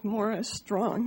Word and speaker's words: Maurice Strong: there was a man that died recently named Morris Strong, spelled like Maurice Strong, but pronounced Morris Strong.